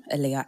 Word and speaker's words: Aahliyah: All of that stuff is my full name, Aliyah Amirat, and that's spelled A-A-H-L-I-Y-A-H, surname A-M-E-R-A-T.